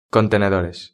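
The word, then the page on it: contenedores: Spanish (Spain)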